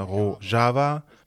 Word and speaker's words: Rojava: Der Grund für den Namen Rojava oder Rojava, beide Formen der Aussprache gibt es, ist, dass es die kurdischen Worte für Westen und Kurdistan sind.